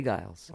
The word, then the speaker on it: Giles: Yeah, G-I-L-E-S. – So why isn't it Giles, like J. Giles? – Well, Matt Giles. – It's G like giraffe. – Oh, I think it's spelled different. – Well, it's a soft G.